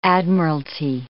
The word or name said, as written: admiralty